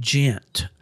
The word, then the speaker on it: Ghent: The nearest, there's an exit there, I'm trying to say, in a town called Ghent, and that's spelled with an H, G-H-E-N-T.